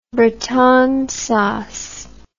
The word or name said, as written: bretonne sauce